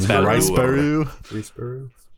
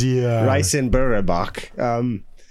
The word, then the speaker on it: Riseborough: Starring Andrea Riseborough. I'm hoping I said that right. If I'm not, sorry, Andrea. – You said it like a Japanese person. – Riseborough. – Yeah, it's Riseborough.